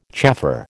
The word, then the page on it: chaffer: American English